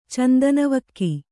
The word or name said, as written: ಚಂದನವಕ್ಕಿ